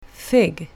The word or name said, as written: fig